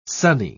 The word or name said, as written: sonny